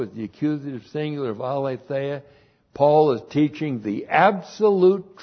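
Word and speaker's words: aletheia (ἀλήθεια): Three, the direct object of Lego one of my favorite words, aletheia. A-L, long E-T-H-E-I-A. Aletheia.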